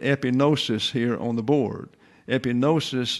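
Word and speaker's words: Epinosis (ἐπίγνωσις): So here we have epi. The I there is a short I sound. That is the accented verb, the accented syllable. Epinosis. Epinosis, therefore, the G is not pronounced, by the way, here.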